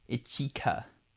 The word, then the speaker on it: Ichika: Ichika. That's how it's pronounced. Was it.